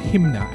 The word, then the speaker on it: Hmyna: Got a new release from, I think it's pronounced Hymna, H M Y N A, vocalist from, I think living in London now, but it's from, lived all across the world, and on Lee Bright's new label.